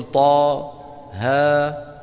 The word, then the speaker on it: طه: هذه 5 الحاء والياء والطاء والهاء والراء، هذه 5 تنطق على 2 حروف. على كم حرف؟ على الحرف الثاني منهما حرف مد، يعني نقول طا ها يا حا را، هذه 5 هكذا تنطق. فطالما أنها 2 حروف والثاني حرف مد، معناتها كم حركة مد؟ 2 حركات، على أنها مد شو؟ طبيعي، يعني بنقول طاها، تمام؟